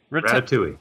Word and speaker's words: Rattata: Rattata.